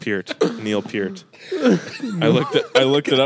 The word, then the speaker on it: Peart: Like, there's an interview of Geddy Lee saying it's pronounced Peart.